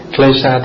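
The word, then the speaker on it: kleśa: Tiếng Pali nó đọc là kilesa, còn tiếng Sanskrit là klesha.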